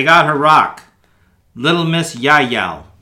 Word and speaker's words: Yael: Little Miss Jail. – Yael. – No, it's a J. – Right. I looked it up because it's a biblical thing, and it's actually from the Bible, but it's pronounced Yael.